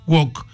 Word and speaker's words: woke: Traté de explicarles hoy muy brevemente qué significa el término WOC, que se escribe, es un término en inglés que se escribe W-O-K-E, WOKE, se pronuncia WOC.